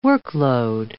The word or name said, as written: workload